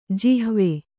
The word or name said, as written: ಜಿಹ್ವ